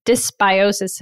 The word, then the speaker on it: dysbiosis: So you've got a dog that is in dysbiosis.